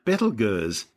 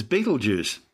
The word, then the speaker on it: Betelgeuse: Well, that's the red supergiant Betelgeuse, better known to most people these days as Betelgeuse. Don't say it three times. In ancient times, before centuries of mispronunciation, the name started out as Yptalgalza.